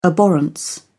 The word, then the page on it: abhorrence: British English